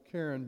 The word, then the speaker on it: Karen: And about several months after that prayer was prayed, five Karen people came, K-A-R-E-N. It's like Karen, but pronounced Karen, from Burma.